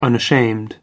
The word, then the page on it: unashamed: American English